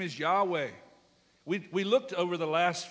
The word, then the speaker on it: Yahweh: That's really a man-made term, because the real reality of that is it's Yahweh, Y-H-W-H, no vowels. The scribes would come along in those days and they would point a pronunciation beneath the bottom line. So it's Y-H-W-H, and they put a there, somewhat similar to ours, and an E, so it's Yahweh, and we somehow Latinized that.